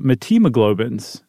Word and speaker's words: methemoglobins: Our bodies also produce something else called uh, methemoglobins, I guess is how you would pronounce it. That is not how I've been pronouncing it in my head.